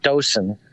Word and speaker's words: Dothan: I mean, in Hebrew, it would be, you know, it would be you know, most people pronounce it Dotan, or, you know, or Dotan, but okay.